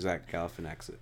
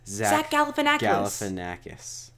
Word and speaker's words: Galifianakis: I think Zach Galifianakis. Yeah. – Zach no one can say his name. – Zach Galifianakis. – No, Zach Galifianakis. – You sure? – Zach Galifianakis.